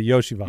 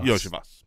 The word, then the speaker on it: Iosivas: Um, yeah, I O S I V A S. Um, which says obviously Yoshivas. – Yoshivas.